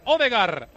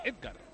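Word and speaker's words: Ødegaard: El córner para Odegar, aunque dice Sande que se dice Edgar, se pronuncia Edgar.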